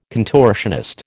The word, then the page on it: contortionist: American English